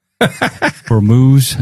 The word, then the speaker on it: Hormuz: The Straits of Hormuz. Mm-hmm. How do you say it? We argue on how we say it. – I'd just agree with you on that one. Hormuz. – Hormuz.